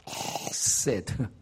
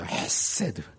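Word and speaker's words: 헤세드 (חסד): 이 단어를 헤세드라고 말합니다. 헤세드. 이게 가래가 끌어나오는 그런 발음이에요. 헤세드. 아주 발음하기가 힘들어요.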